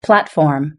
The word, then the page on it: platform: British English